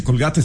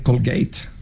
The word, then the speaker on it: Colgate: Entonces, bueno, no se dice Colgate, se dice Colgate.